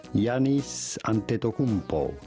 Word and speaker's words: Giannis Antetokounmpo: Simply Giannis Antetokounmpo.